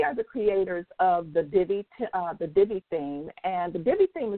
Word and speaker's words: Divi: Now, Divi is spelled D-I, V as in Victor, I, Divi.